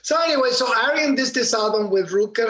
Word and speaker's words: Arjen: No, it's A-R-J-E-N. It's Arjen, but you pronounce it Arjen. The J is an I in Europe. – What's his last name? – Lucassen. L-U-C-A-S-S.